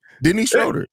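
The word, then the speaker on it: Dennis Schröder: Dennis Schroeder. That's how you say his name.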